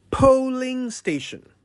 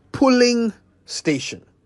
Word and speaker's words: polling station: Repeat after me. Polling station. Polling station. P-O-L-L-I-N-G. Polling station. Not pulling station. Today's Say It Right is for everyone's attention, especially my colleague journalists, who sometimes say pulling station when they mean polling station.